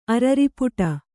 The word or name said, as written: ಅರರಿಪುಟ